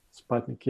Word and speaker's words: Sputnik: I think we call it Sputnik. – Sputnik, yeah, because it's U and U pronounces like A and we pronounce like U.